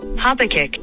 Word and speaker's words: Habakkuk: Habakkuk. – Oh, Habakkuk.